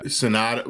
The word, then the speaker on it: Sanada: We'll get into what I didn't like, but I liked, I don't know how to pronounce his name, but Hiroyuki Sanada. – Yeah. Sanada.